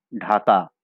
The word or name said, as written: ঢাকা